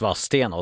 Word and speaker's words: Valstena: Johan Asprant var tillbaka i båset lika snabbt som Andreas Harnes kan komma till Linköping och lära sig att Valstena uttalas Vassstena och sen var det adjö igen.